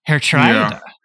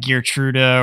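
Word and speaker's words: Geertruida: The pronunciation of his name is, I got to pull it up because Kevin says Herr Trida. – Hertrida. – So I've been saying like Gertruda or Gear the Truth or whatever.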